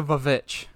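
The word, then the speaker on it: VVitch: That's the only way to pronounce the Vavitch. – The Vavitch, yeah. Because that's not two W's. That is not. That's not a W. That's two V's.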